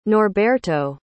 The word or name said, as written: norberto